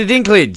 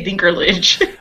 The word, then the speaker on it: Dinklage: Peter Dinklage. – Dinklage, okay, I want to say Dinkerlage, which, but Dinklage, look, okay.